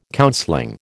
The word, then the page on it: counseling: American English